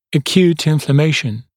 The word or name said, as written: acute inflammation